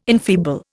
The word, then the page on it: enfeeble: Indian